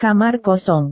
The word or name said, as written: kamar kosong